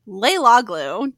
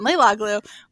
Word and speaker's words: Leilaglu: Leilaglu. Did I say that correctly? – Yes, okay. That was perfect. – Carolyn Leilaglu, which is spelled Leilaglu for those taking notes.